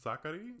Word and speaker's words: Sakkari: Sakkari? – Yeah, I like it. You roll the R a little bit. You roll it a little bit.